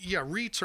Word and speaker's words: REIT: So, REITs are definitely involved in this, and that's what you pronounce it REIT, and it's real estate investment trust.